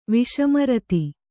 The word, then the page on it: ವಿಷಮರ್ತು: kannada